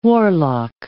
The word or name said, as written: warlock